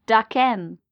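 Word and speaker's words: Daken: Which, let me just, it is Dakin.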